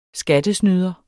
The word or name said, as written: skattesnyder